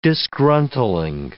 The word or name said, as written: disgruntling